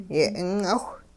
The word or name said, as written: yeh'ing'awh